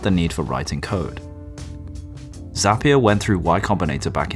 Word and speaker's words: Zapier: Zapier, which is not pronounced Zapier, by the way, I made this mistake,